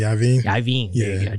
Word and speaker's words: Iovine: And he quotes Jimmy Iovine. Am I saying that right? – Jimmy Iovine.